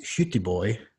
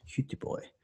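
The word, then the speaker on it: Göteborg: And my wife and I actually traveled to the float conference in Gothenburg, Sweden, which, if you're Swedish, is pronounced Hjuteboy. Hjuteboy.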